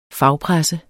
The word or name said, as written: fagpresse